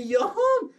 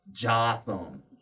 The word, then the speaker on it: Jotham: Is that name spelled J-O-T-H-A-M? She's like, yes. I said, that is pronounced Jotham. You know, like your thumb? You know, like that scene from Batman? Just put take the G out, put in a J?